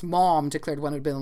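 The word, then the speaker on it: Maugham: Somerset, M-A-U-G-H-A-M. I've always said mom. – Very good. It's a stretched out version of mom with the aw sound held longer than in mom.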